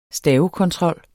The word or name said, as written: stavekontrol